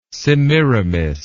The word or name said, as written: semiramis